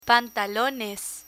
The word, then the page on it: pantalones: Spanish